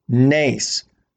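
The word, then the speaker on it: Nace (נס): Spells Nes, N-E-S, pronounced Nace, which is miracle in Hebrew.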